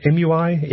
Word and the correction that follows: EMUI: MUI? – EMUI, actually. EMUI.